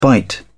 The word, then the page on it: bite: British English